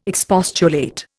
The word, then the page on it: expostulate: Indian